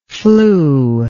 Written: flued

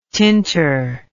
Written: tinter

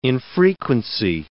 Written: infrequency

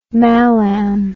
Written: malam

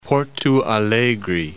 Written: Porto Alegre

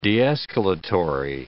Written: de-escalatory

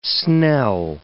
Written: snell

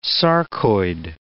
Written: sarcoid

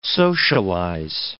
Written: socialize

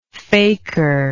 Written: faker

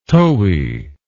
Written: towie

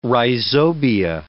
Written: rhizobia

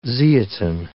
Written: zeatin

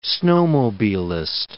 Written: snowmobilist

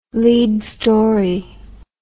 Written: lead story

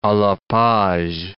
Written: a la page

